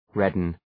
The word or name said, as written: redden